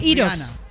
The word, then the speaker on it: Hyrox: Sí, pues, se escribe Hyrox.